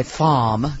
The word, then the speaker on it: femme: Or I would like to say FOMME, because it's F-E-M-M-E, the French pronunciation.